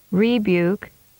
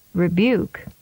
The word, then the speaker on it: rebuke: The most common mistake on this pattern is to put too much emphasis on the first syllable, as in these examples. – Deter instead of deter. Rebuke instead of rebuke. Decline instead of decline.